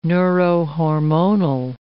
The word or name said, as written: neurohormonal